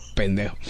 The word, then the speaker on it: pendejo: It rhymes with conejo. – Oh, pendejo. Pendejo.